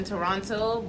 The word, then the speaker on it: Toronto: Like, you guys say the all the T's in Toronto, but apparently over there they say Toronto.